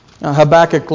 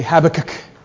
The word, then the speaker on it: Habakkuk: In Britain, they pronounce Habakkuk like it sounds like they have something caught in their throat. They say Habakkuk.